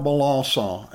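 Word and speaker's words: Melançon: His guitar is Gerard Milasa, and that's spelled M-E-L-A-N-C-O-N.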